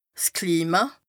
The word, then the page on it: Klima: Bas Rhin